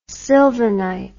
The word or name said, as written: sylvinite